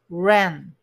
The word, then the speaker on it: ran: 現在形だと run, u ですが、え真ん中が A になって、発音は E の口の形で下顎を下げて、え、 ran, ran, ran out of money となると、使い果たしたという過去ですね。